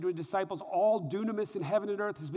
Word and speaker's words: dunamis: The word power here is the Greek word dunamis. It's spelled like dynamis, but it's pronounced dunamis,